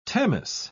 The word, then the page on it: tamis: British English